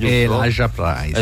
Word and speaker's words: Elijah Price: Che interpreta, come si pronuncia? Elijah Price, Elijah Price. – Esatto, un uomo che non può prendere urti, altrimenti insomma si sgretola ed è vera cosa.